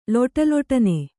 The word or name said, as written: ಲಟಲಟನೆ